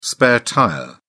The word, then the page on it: spare-tyre: British English